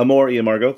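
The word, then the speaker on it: Amor y Amargo: It doesn't involve him necessarily, but his brilliant creation, Amaro e Amargo. Am I saying that correctly? – Southern? Amor e Amargo. Close enough.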